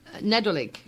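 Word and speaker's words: Nadolig: Nedolig.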